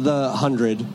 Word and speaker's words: The 100: But tell me about, so you're working on The 100. – Yes. – Am I saying that correctly? – That is correct, yes.